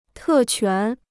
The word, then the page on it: 特权: tè quán